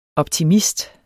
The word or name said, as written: optimist